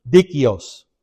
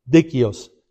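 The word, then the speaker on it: dikaios: It comes from the Greek word dikios. Man, I said it right the first time. Dikios.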